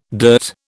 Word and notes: dirt: British English

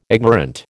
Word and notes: ignorant: American English

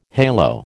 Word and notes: halo: American English

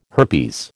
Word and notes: herpes: American English

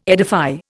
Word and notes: edify: Indian

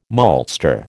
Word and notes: maltster: American English

